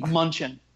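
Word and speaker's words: Mnuchin: So news came out recently, and this guy, Steve Munchin, is that how you say his name? – He doesn't say it so tentatively. It's Steve Munchin.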